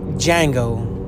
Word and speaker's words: Django: He's like, Django with a D. He's like, and then he turns to him and says, the D is silent.